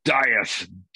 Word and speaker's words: Dieth: And I also really liked Dieth. Am I pronouncing the band properly? Your other projects? – Dieth. Yeah, I was making fun of, while we're shooting the video, I was using my Shakespearean voice.